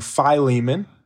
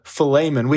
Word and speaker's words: Philemon: We're going to be talking about, well, what I grew up calling Philemon. In Greek, Philemon.